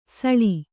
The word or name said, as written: ಸಳಿ